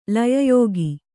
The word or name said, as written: ಲಯಯೋಗ